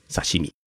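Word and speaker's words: さしみ: 生鱼片啊，是我们中国人的叫法，在日本呢叫做刺身，日语念作是沙西米。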